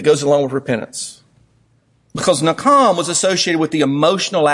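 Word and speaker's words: nakam (נחם): The first Hebrew word that's used for repentance is nakam. You would spell it N-A-K-H-A-M. Nakham. I'm having ham for lunch, so ham's on my mind. But nakam is how you would say it.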